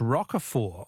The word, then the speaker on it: Roquefort: The French Roquefort, also with a silent T, is very smelly.